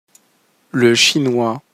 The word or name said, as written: le chinois